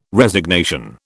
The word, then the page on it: Resignation: British English